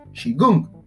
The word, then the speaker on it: Qigong: These exercises are collectively known as Qigong, pronounced Qigong, directly translates as energy work, but the idea is keeping the body and mind operating at an optimum.